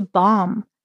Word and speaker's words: bomb: And notice that bomb is spelled B O M B. So it's got a B on the end, but the B is silent. You don't need to pronounce the B.